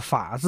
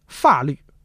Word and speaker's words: 法: 但是需要说明的是啊，这个法律的法字啊应该读三声，目前的标准读音是三声。但是过去呢这个字呢是读四声，法律，法国，在我国台湾呢这字现在还是读四声。